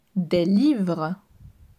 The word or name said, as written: Des livres